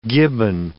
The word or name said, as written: gibbon